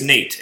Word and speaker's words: Nate: My name is Nate, N-A-T-E. – N-A-T.